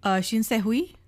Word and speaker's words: Shin Se-hui: The high school student, her name is, how do you pronounce it? – Shin Se-hui? – Yes.